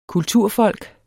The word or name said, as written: kulturfolk